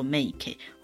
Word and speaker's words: 名家: 有名的人家，名家哈，念作 make，或者是旧家、良家这样的称呼方式。